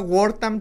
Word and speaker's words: Wortham: Se llama Wortham Theater. Se escribe W-O-R-T-H-A-M. Wortham, así, W-O-R-T-H-A-M.